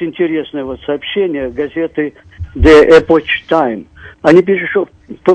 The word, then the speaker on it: Epoch: «Epoch».